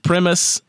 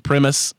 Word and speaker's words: Primus: The announcer said Primus. I watched the fight. They called him Brent Primus, unless it's in Europe. Or a European mispronunciation, they called him Brent Primus. So his mama called him Primus, I'ma call him Primus.